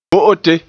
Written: bo'ote

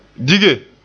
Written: dige

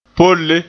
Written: polle

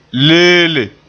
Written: leele

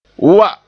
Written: wa

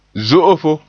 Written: zo'ofo

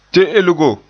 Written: te'elgo

